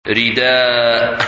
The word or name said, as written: Rida